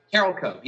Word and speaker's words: Carolco: You recently joined, I don't know if I'm pronouncing this right, Carolco Pictures? – Carolco, yep. Carolco.